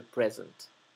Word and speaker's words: present: Each future lies in a state of rest until it is awakened by choices made in the present. Fíjense que present, como tenemos una S entre dos vocales, se pronuncia con la S dura, la S zumbada. No es present, es present, present.